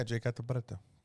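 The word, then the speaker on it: Catta-Preta: How do you say her? Cata Preta. Is that how you say it? She's Brazilian. – Yeah, Jade Cata Preta.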